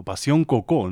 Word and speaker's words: Passion Coco: Passion Coco, o Passion Coco, ¿no? Lo pronunciarían así más bien a la francesa. No hay mucha diferencia, pero bueno, cierto matiz.